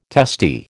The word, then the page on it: testy: American English